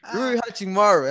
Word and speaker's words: Rui Hachimura: Rui Hachimura. However you say his name. Rui Hachimura. Am I saying it right? – Yes, sir.